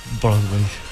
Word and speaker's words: Broadway: ¿Se pronuncia así? – Broadway. – Bueno, usted te pronuncia mejor que yo. – No, pero lo pronunciaste excelente, Sandrín.